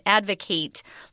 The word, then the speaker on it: advocate: So it's an I sound, a short I, and then a long A. So advocate, short I sound, and then advocate, long A sound.